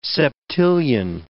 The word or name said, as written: septillion